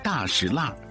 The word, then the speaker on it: 大栅栏: 那我们就从所有的老字号最集中的大栅栏开始吧。走。 – 大石栏读作大石栏，是融合了少数民族方言的北京土话，原本叫做廊坊四条，是前门外一条著名的商业街。